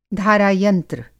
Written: धारा-यंत्र

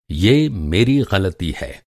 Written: ग़लती

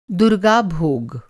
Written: भोग

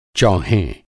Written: चौहें

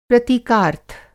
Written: प्रतीकार्थ

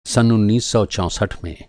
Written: चौंसठ

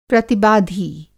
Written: प्रतिबाधी

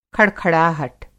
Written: खड़खड़ाहट

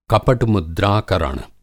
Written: कपट-मुद्राकरण

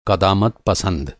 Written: क़दामत पसंद

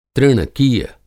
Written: तृणकीय

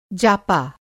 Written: जापा

